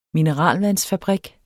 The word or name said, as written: mineralvandsfabrik